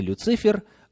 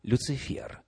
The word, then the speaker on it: Люцифер: Кстати, согласно орфографическому словарю, ударение нужно ставить именно на последний слог. не Люцифер, а Люцифер.